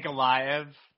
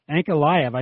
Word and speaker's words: Ankalaev: We got Magomed Ankilov, Ankilov, Ankilov. Why can't I say his name? An- Ankalaev. – Ankalaev.